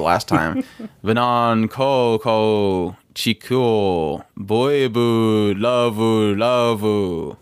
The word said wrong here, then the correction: Love Love: You've gotta say it. Love love. – Oh, so Venon Koko Chiku Boibu Lava Lava. No. Love love.